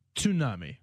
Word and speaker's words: tsunami: Like, I know from George Takei from Star Trek, Captain Sulu, that we all say tsunami wrong. It is? – Tsunami. – Yeah, you pronounce the T.